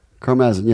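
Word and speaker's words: Karmazin: Mel Carmazin. – Carmazin, yeah.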